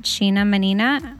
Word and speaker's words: Sheena Mannina: You can find me on Instagram at Sheena Mannina. Yes, that's my real name. – I love that it rhymes.